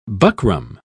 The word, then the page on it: buckram: British English